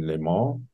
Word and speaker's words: Le Mans: Ich fand Französisch immer doof, deswegen hoffe ich, dass ich es richtig ausspreche. – Le Mans. – Ja, das stimmt, glaube ich. so wird, wird, es auch in dem Film äh, ausgesprochen. In, ja. in, in, der Synchronfassung jedenfalls.